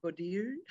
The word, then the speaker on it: Bourdieu: Well, actually, Pierre Bourdieu, B-O-U-R-D-I-E-U, Bourdieu.